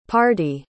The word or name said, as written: pardie